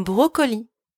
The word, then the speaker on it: brocoli: Un brocoli. A broccoli. You pronounce it the same as the English version, but it's written differently. Un brocoli with only one C.